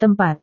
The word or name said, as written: tempat